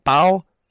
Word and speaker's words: Bao: B-A-O. How do you say that? Bao? – Yeah, Bao, yeah.